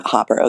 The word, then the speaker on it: Hopper: Is it Hopper or Hooper? – Yeah, Hopper.